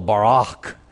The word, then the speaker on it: Barak: You should pronounce it as if it were B-A-R-A-A-A-C-H, with that guttural Barak.